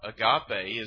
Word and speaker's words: agape: A-G-A-P-E. And that's a long E at the end. Agape.